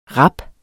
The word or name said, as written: rap